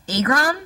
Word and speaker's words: Agron: Have you heard Diana of Aragon pronounce her name? She actually says Agron. – Agron? – Agron. – Oh, God. – She says it with an A, yeah.